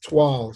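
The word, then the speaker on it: Toile: It's pronounced Twal. Sheila will kill me. Twal. – Okay, thank you. Twal. Thank you.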